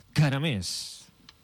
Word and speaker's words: Caramés: Mira, Antonio Caramés. – Antonio Caramés. – Sí, Caramés. C-A-R-A-M-E-S. – C-A-R-A? – No, C-A-R-A...